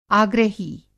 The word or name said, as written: आग्रही